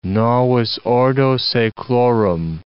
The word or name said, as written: novus ordo seclorum